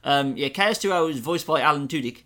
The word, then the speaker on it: Alan Tudyk: It's Alan Tudyk.